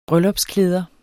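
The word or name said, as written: bryllupsklæder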